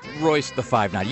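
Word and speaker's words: Royce da 5'9: Apparently, that hip-hopper is just referred to as 5'9", or Royce the 5'9". You don't pronounce the feet or inches,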